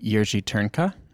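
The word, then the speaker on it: Jiří Trnka: And you are, Irena, you are the guest curator for this exciting series called The Puppet Master, The Complete Jerzy Turnka. Did I say that right? – You've said it perfectly.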